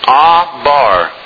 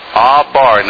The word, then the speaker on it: abar: The Hebrew word, if you're taking notes, the Hebrew word here used is abar, which means to cross over, to pass over, and it's pronounced abar, abar.